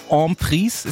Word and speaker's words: Emprise: Und er hat einen Song, der heißt Emprise. Ist das richtig so ausgesprochen? – Oui, oui, monsieur.